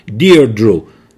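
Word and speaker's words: Deirdre: اینم اسمش رو من کلی تو اینترنت سرچ کردم که بالاخره درست چجوری باید اسم رو تلفظ کنیم. دیردرو اسم ایرلندیه، دیردرو بارت، سوپر نورمال استیمولای.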